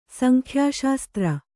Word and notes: ಸಂಖ್ಯಾಶಾಸ್ತ್ರ: sankhyāśastra

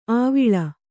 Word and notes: ಆವಿಳ: āviḷa

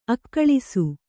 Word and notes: ಅಗಳಿಸು: agaḷisu

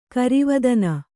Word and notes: ಗರುವತನ: kannada

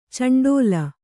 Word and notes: ಚುಂಡಿಲಿ: cunḍili

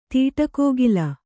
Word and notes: ತೀಟಕೋಗಿಲ: Kannada

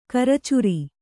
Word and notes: ಕರ್ಚೂರ: Kannada

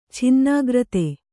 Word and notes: ಛಿನ್ನಾಗ್ರತೆ: chinnāgrate

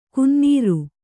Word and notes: ಕುನ್ನೀರು: kunnīru; Kannada

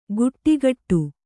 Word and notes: ಗುಟ್ಟಿಗಟ್ಟು: guṭṭigaṭṭu

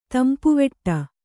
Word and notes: ತಂಪುವಡೆ: tampuvaḍe

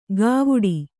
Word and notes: ಗವುಡಿ: gavuḍi

